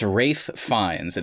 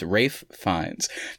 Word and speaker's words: Ralph Fiennes: Everybody gets it wrong. It's Ralph Fiennes. It's spelled Ralph, but his name is Ralph Fiennes.